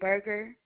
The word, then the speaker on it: Berger: It's Tanasia Berger. Do you need me to spell it? – Yes, ma'am. – All right, Tanasia, T-A-N-A-I-J-A-H, and Berger, B-E-R-G-E-R.